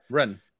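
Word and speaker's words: Rennes: I'm just going to say Rennais because when it's spelled R-E-N-N-E-S, I don't know how to pronounce it because it's. – I think it's Ren, isn't it? – Ren, it is.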